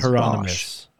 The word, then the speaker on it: Hieronymus: Whatever his first name is, Hieronymus.